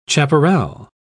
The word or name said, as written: chaparral